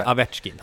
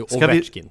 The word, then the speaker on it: Ovechkin: Sluta säga Avechkin. Han heter ju Ovechkin, säger folk.